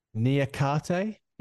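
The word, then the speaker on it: Niakhaté: Now here's an opportunity for you to laugh at my pronunciation. Niakate. – Yeah, that's right.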